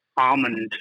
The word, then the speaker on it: Armand: So I was adopted when I was 11, and I swapped out Armand for Carmichael, which, you know, phonetically speaking, I thought was a pretty good trade-up. – Yeah, so it's like almond, as in like the nut.